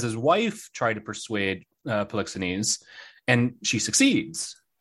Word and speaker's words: Polixenes: Polixenes. Polixenes, the Bohemian king.